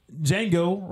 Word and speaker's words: Django: His name is Django Reinhardt. Yes, spelled exactly how the movie is spelled. – What is D-J-A-N-G-O. I'm spelling pronouncing it exactly how the movie is pronounced.